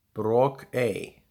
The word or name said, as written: Brokey